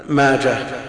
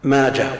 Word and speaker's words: ماجه: يقول كلمة ابن ماجه اسم صاحب السنة تنقط وتنطق تاء في الوصل أو هاء مع التوجيه، ماجه ومنده وداسه هي لا تنقط ولا تنطق، ماجه ومنده وداسة كلها بالهاء.